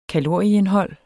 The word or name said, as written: kalorieindhold